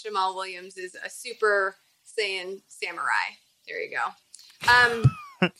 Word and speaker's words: Saiyan: Am I saying that right? Saiyan. – Super Saiyan. – There you go.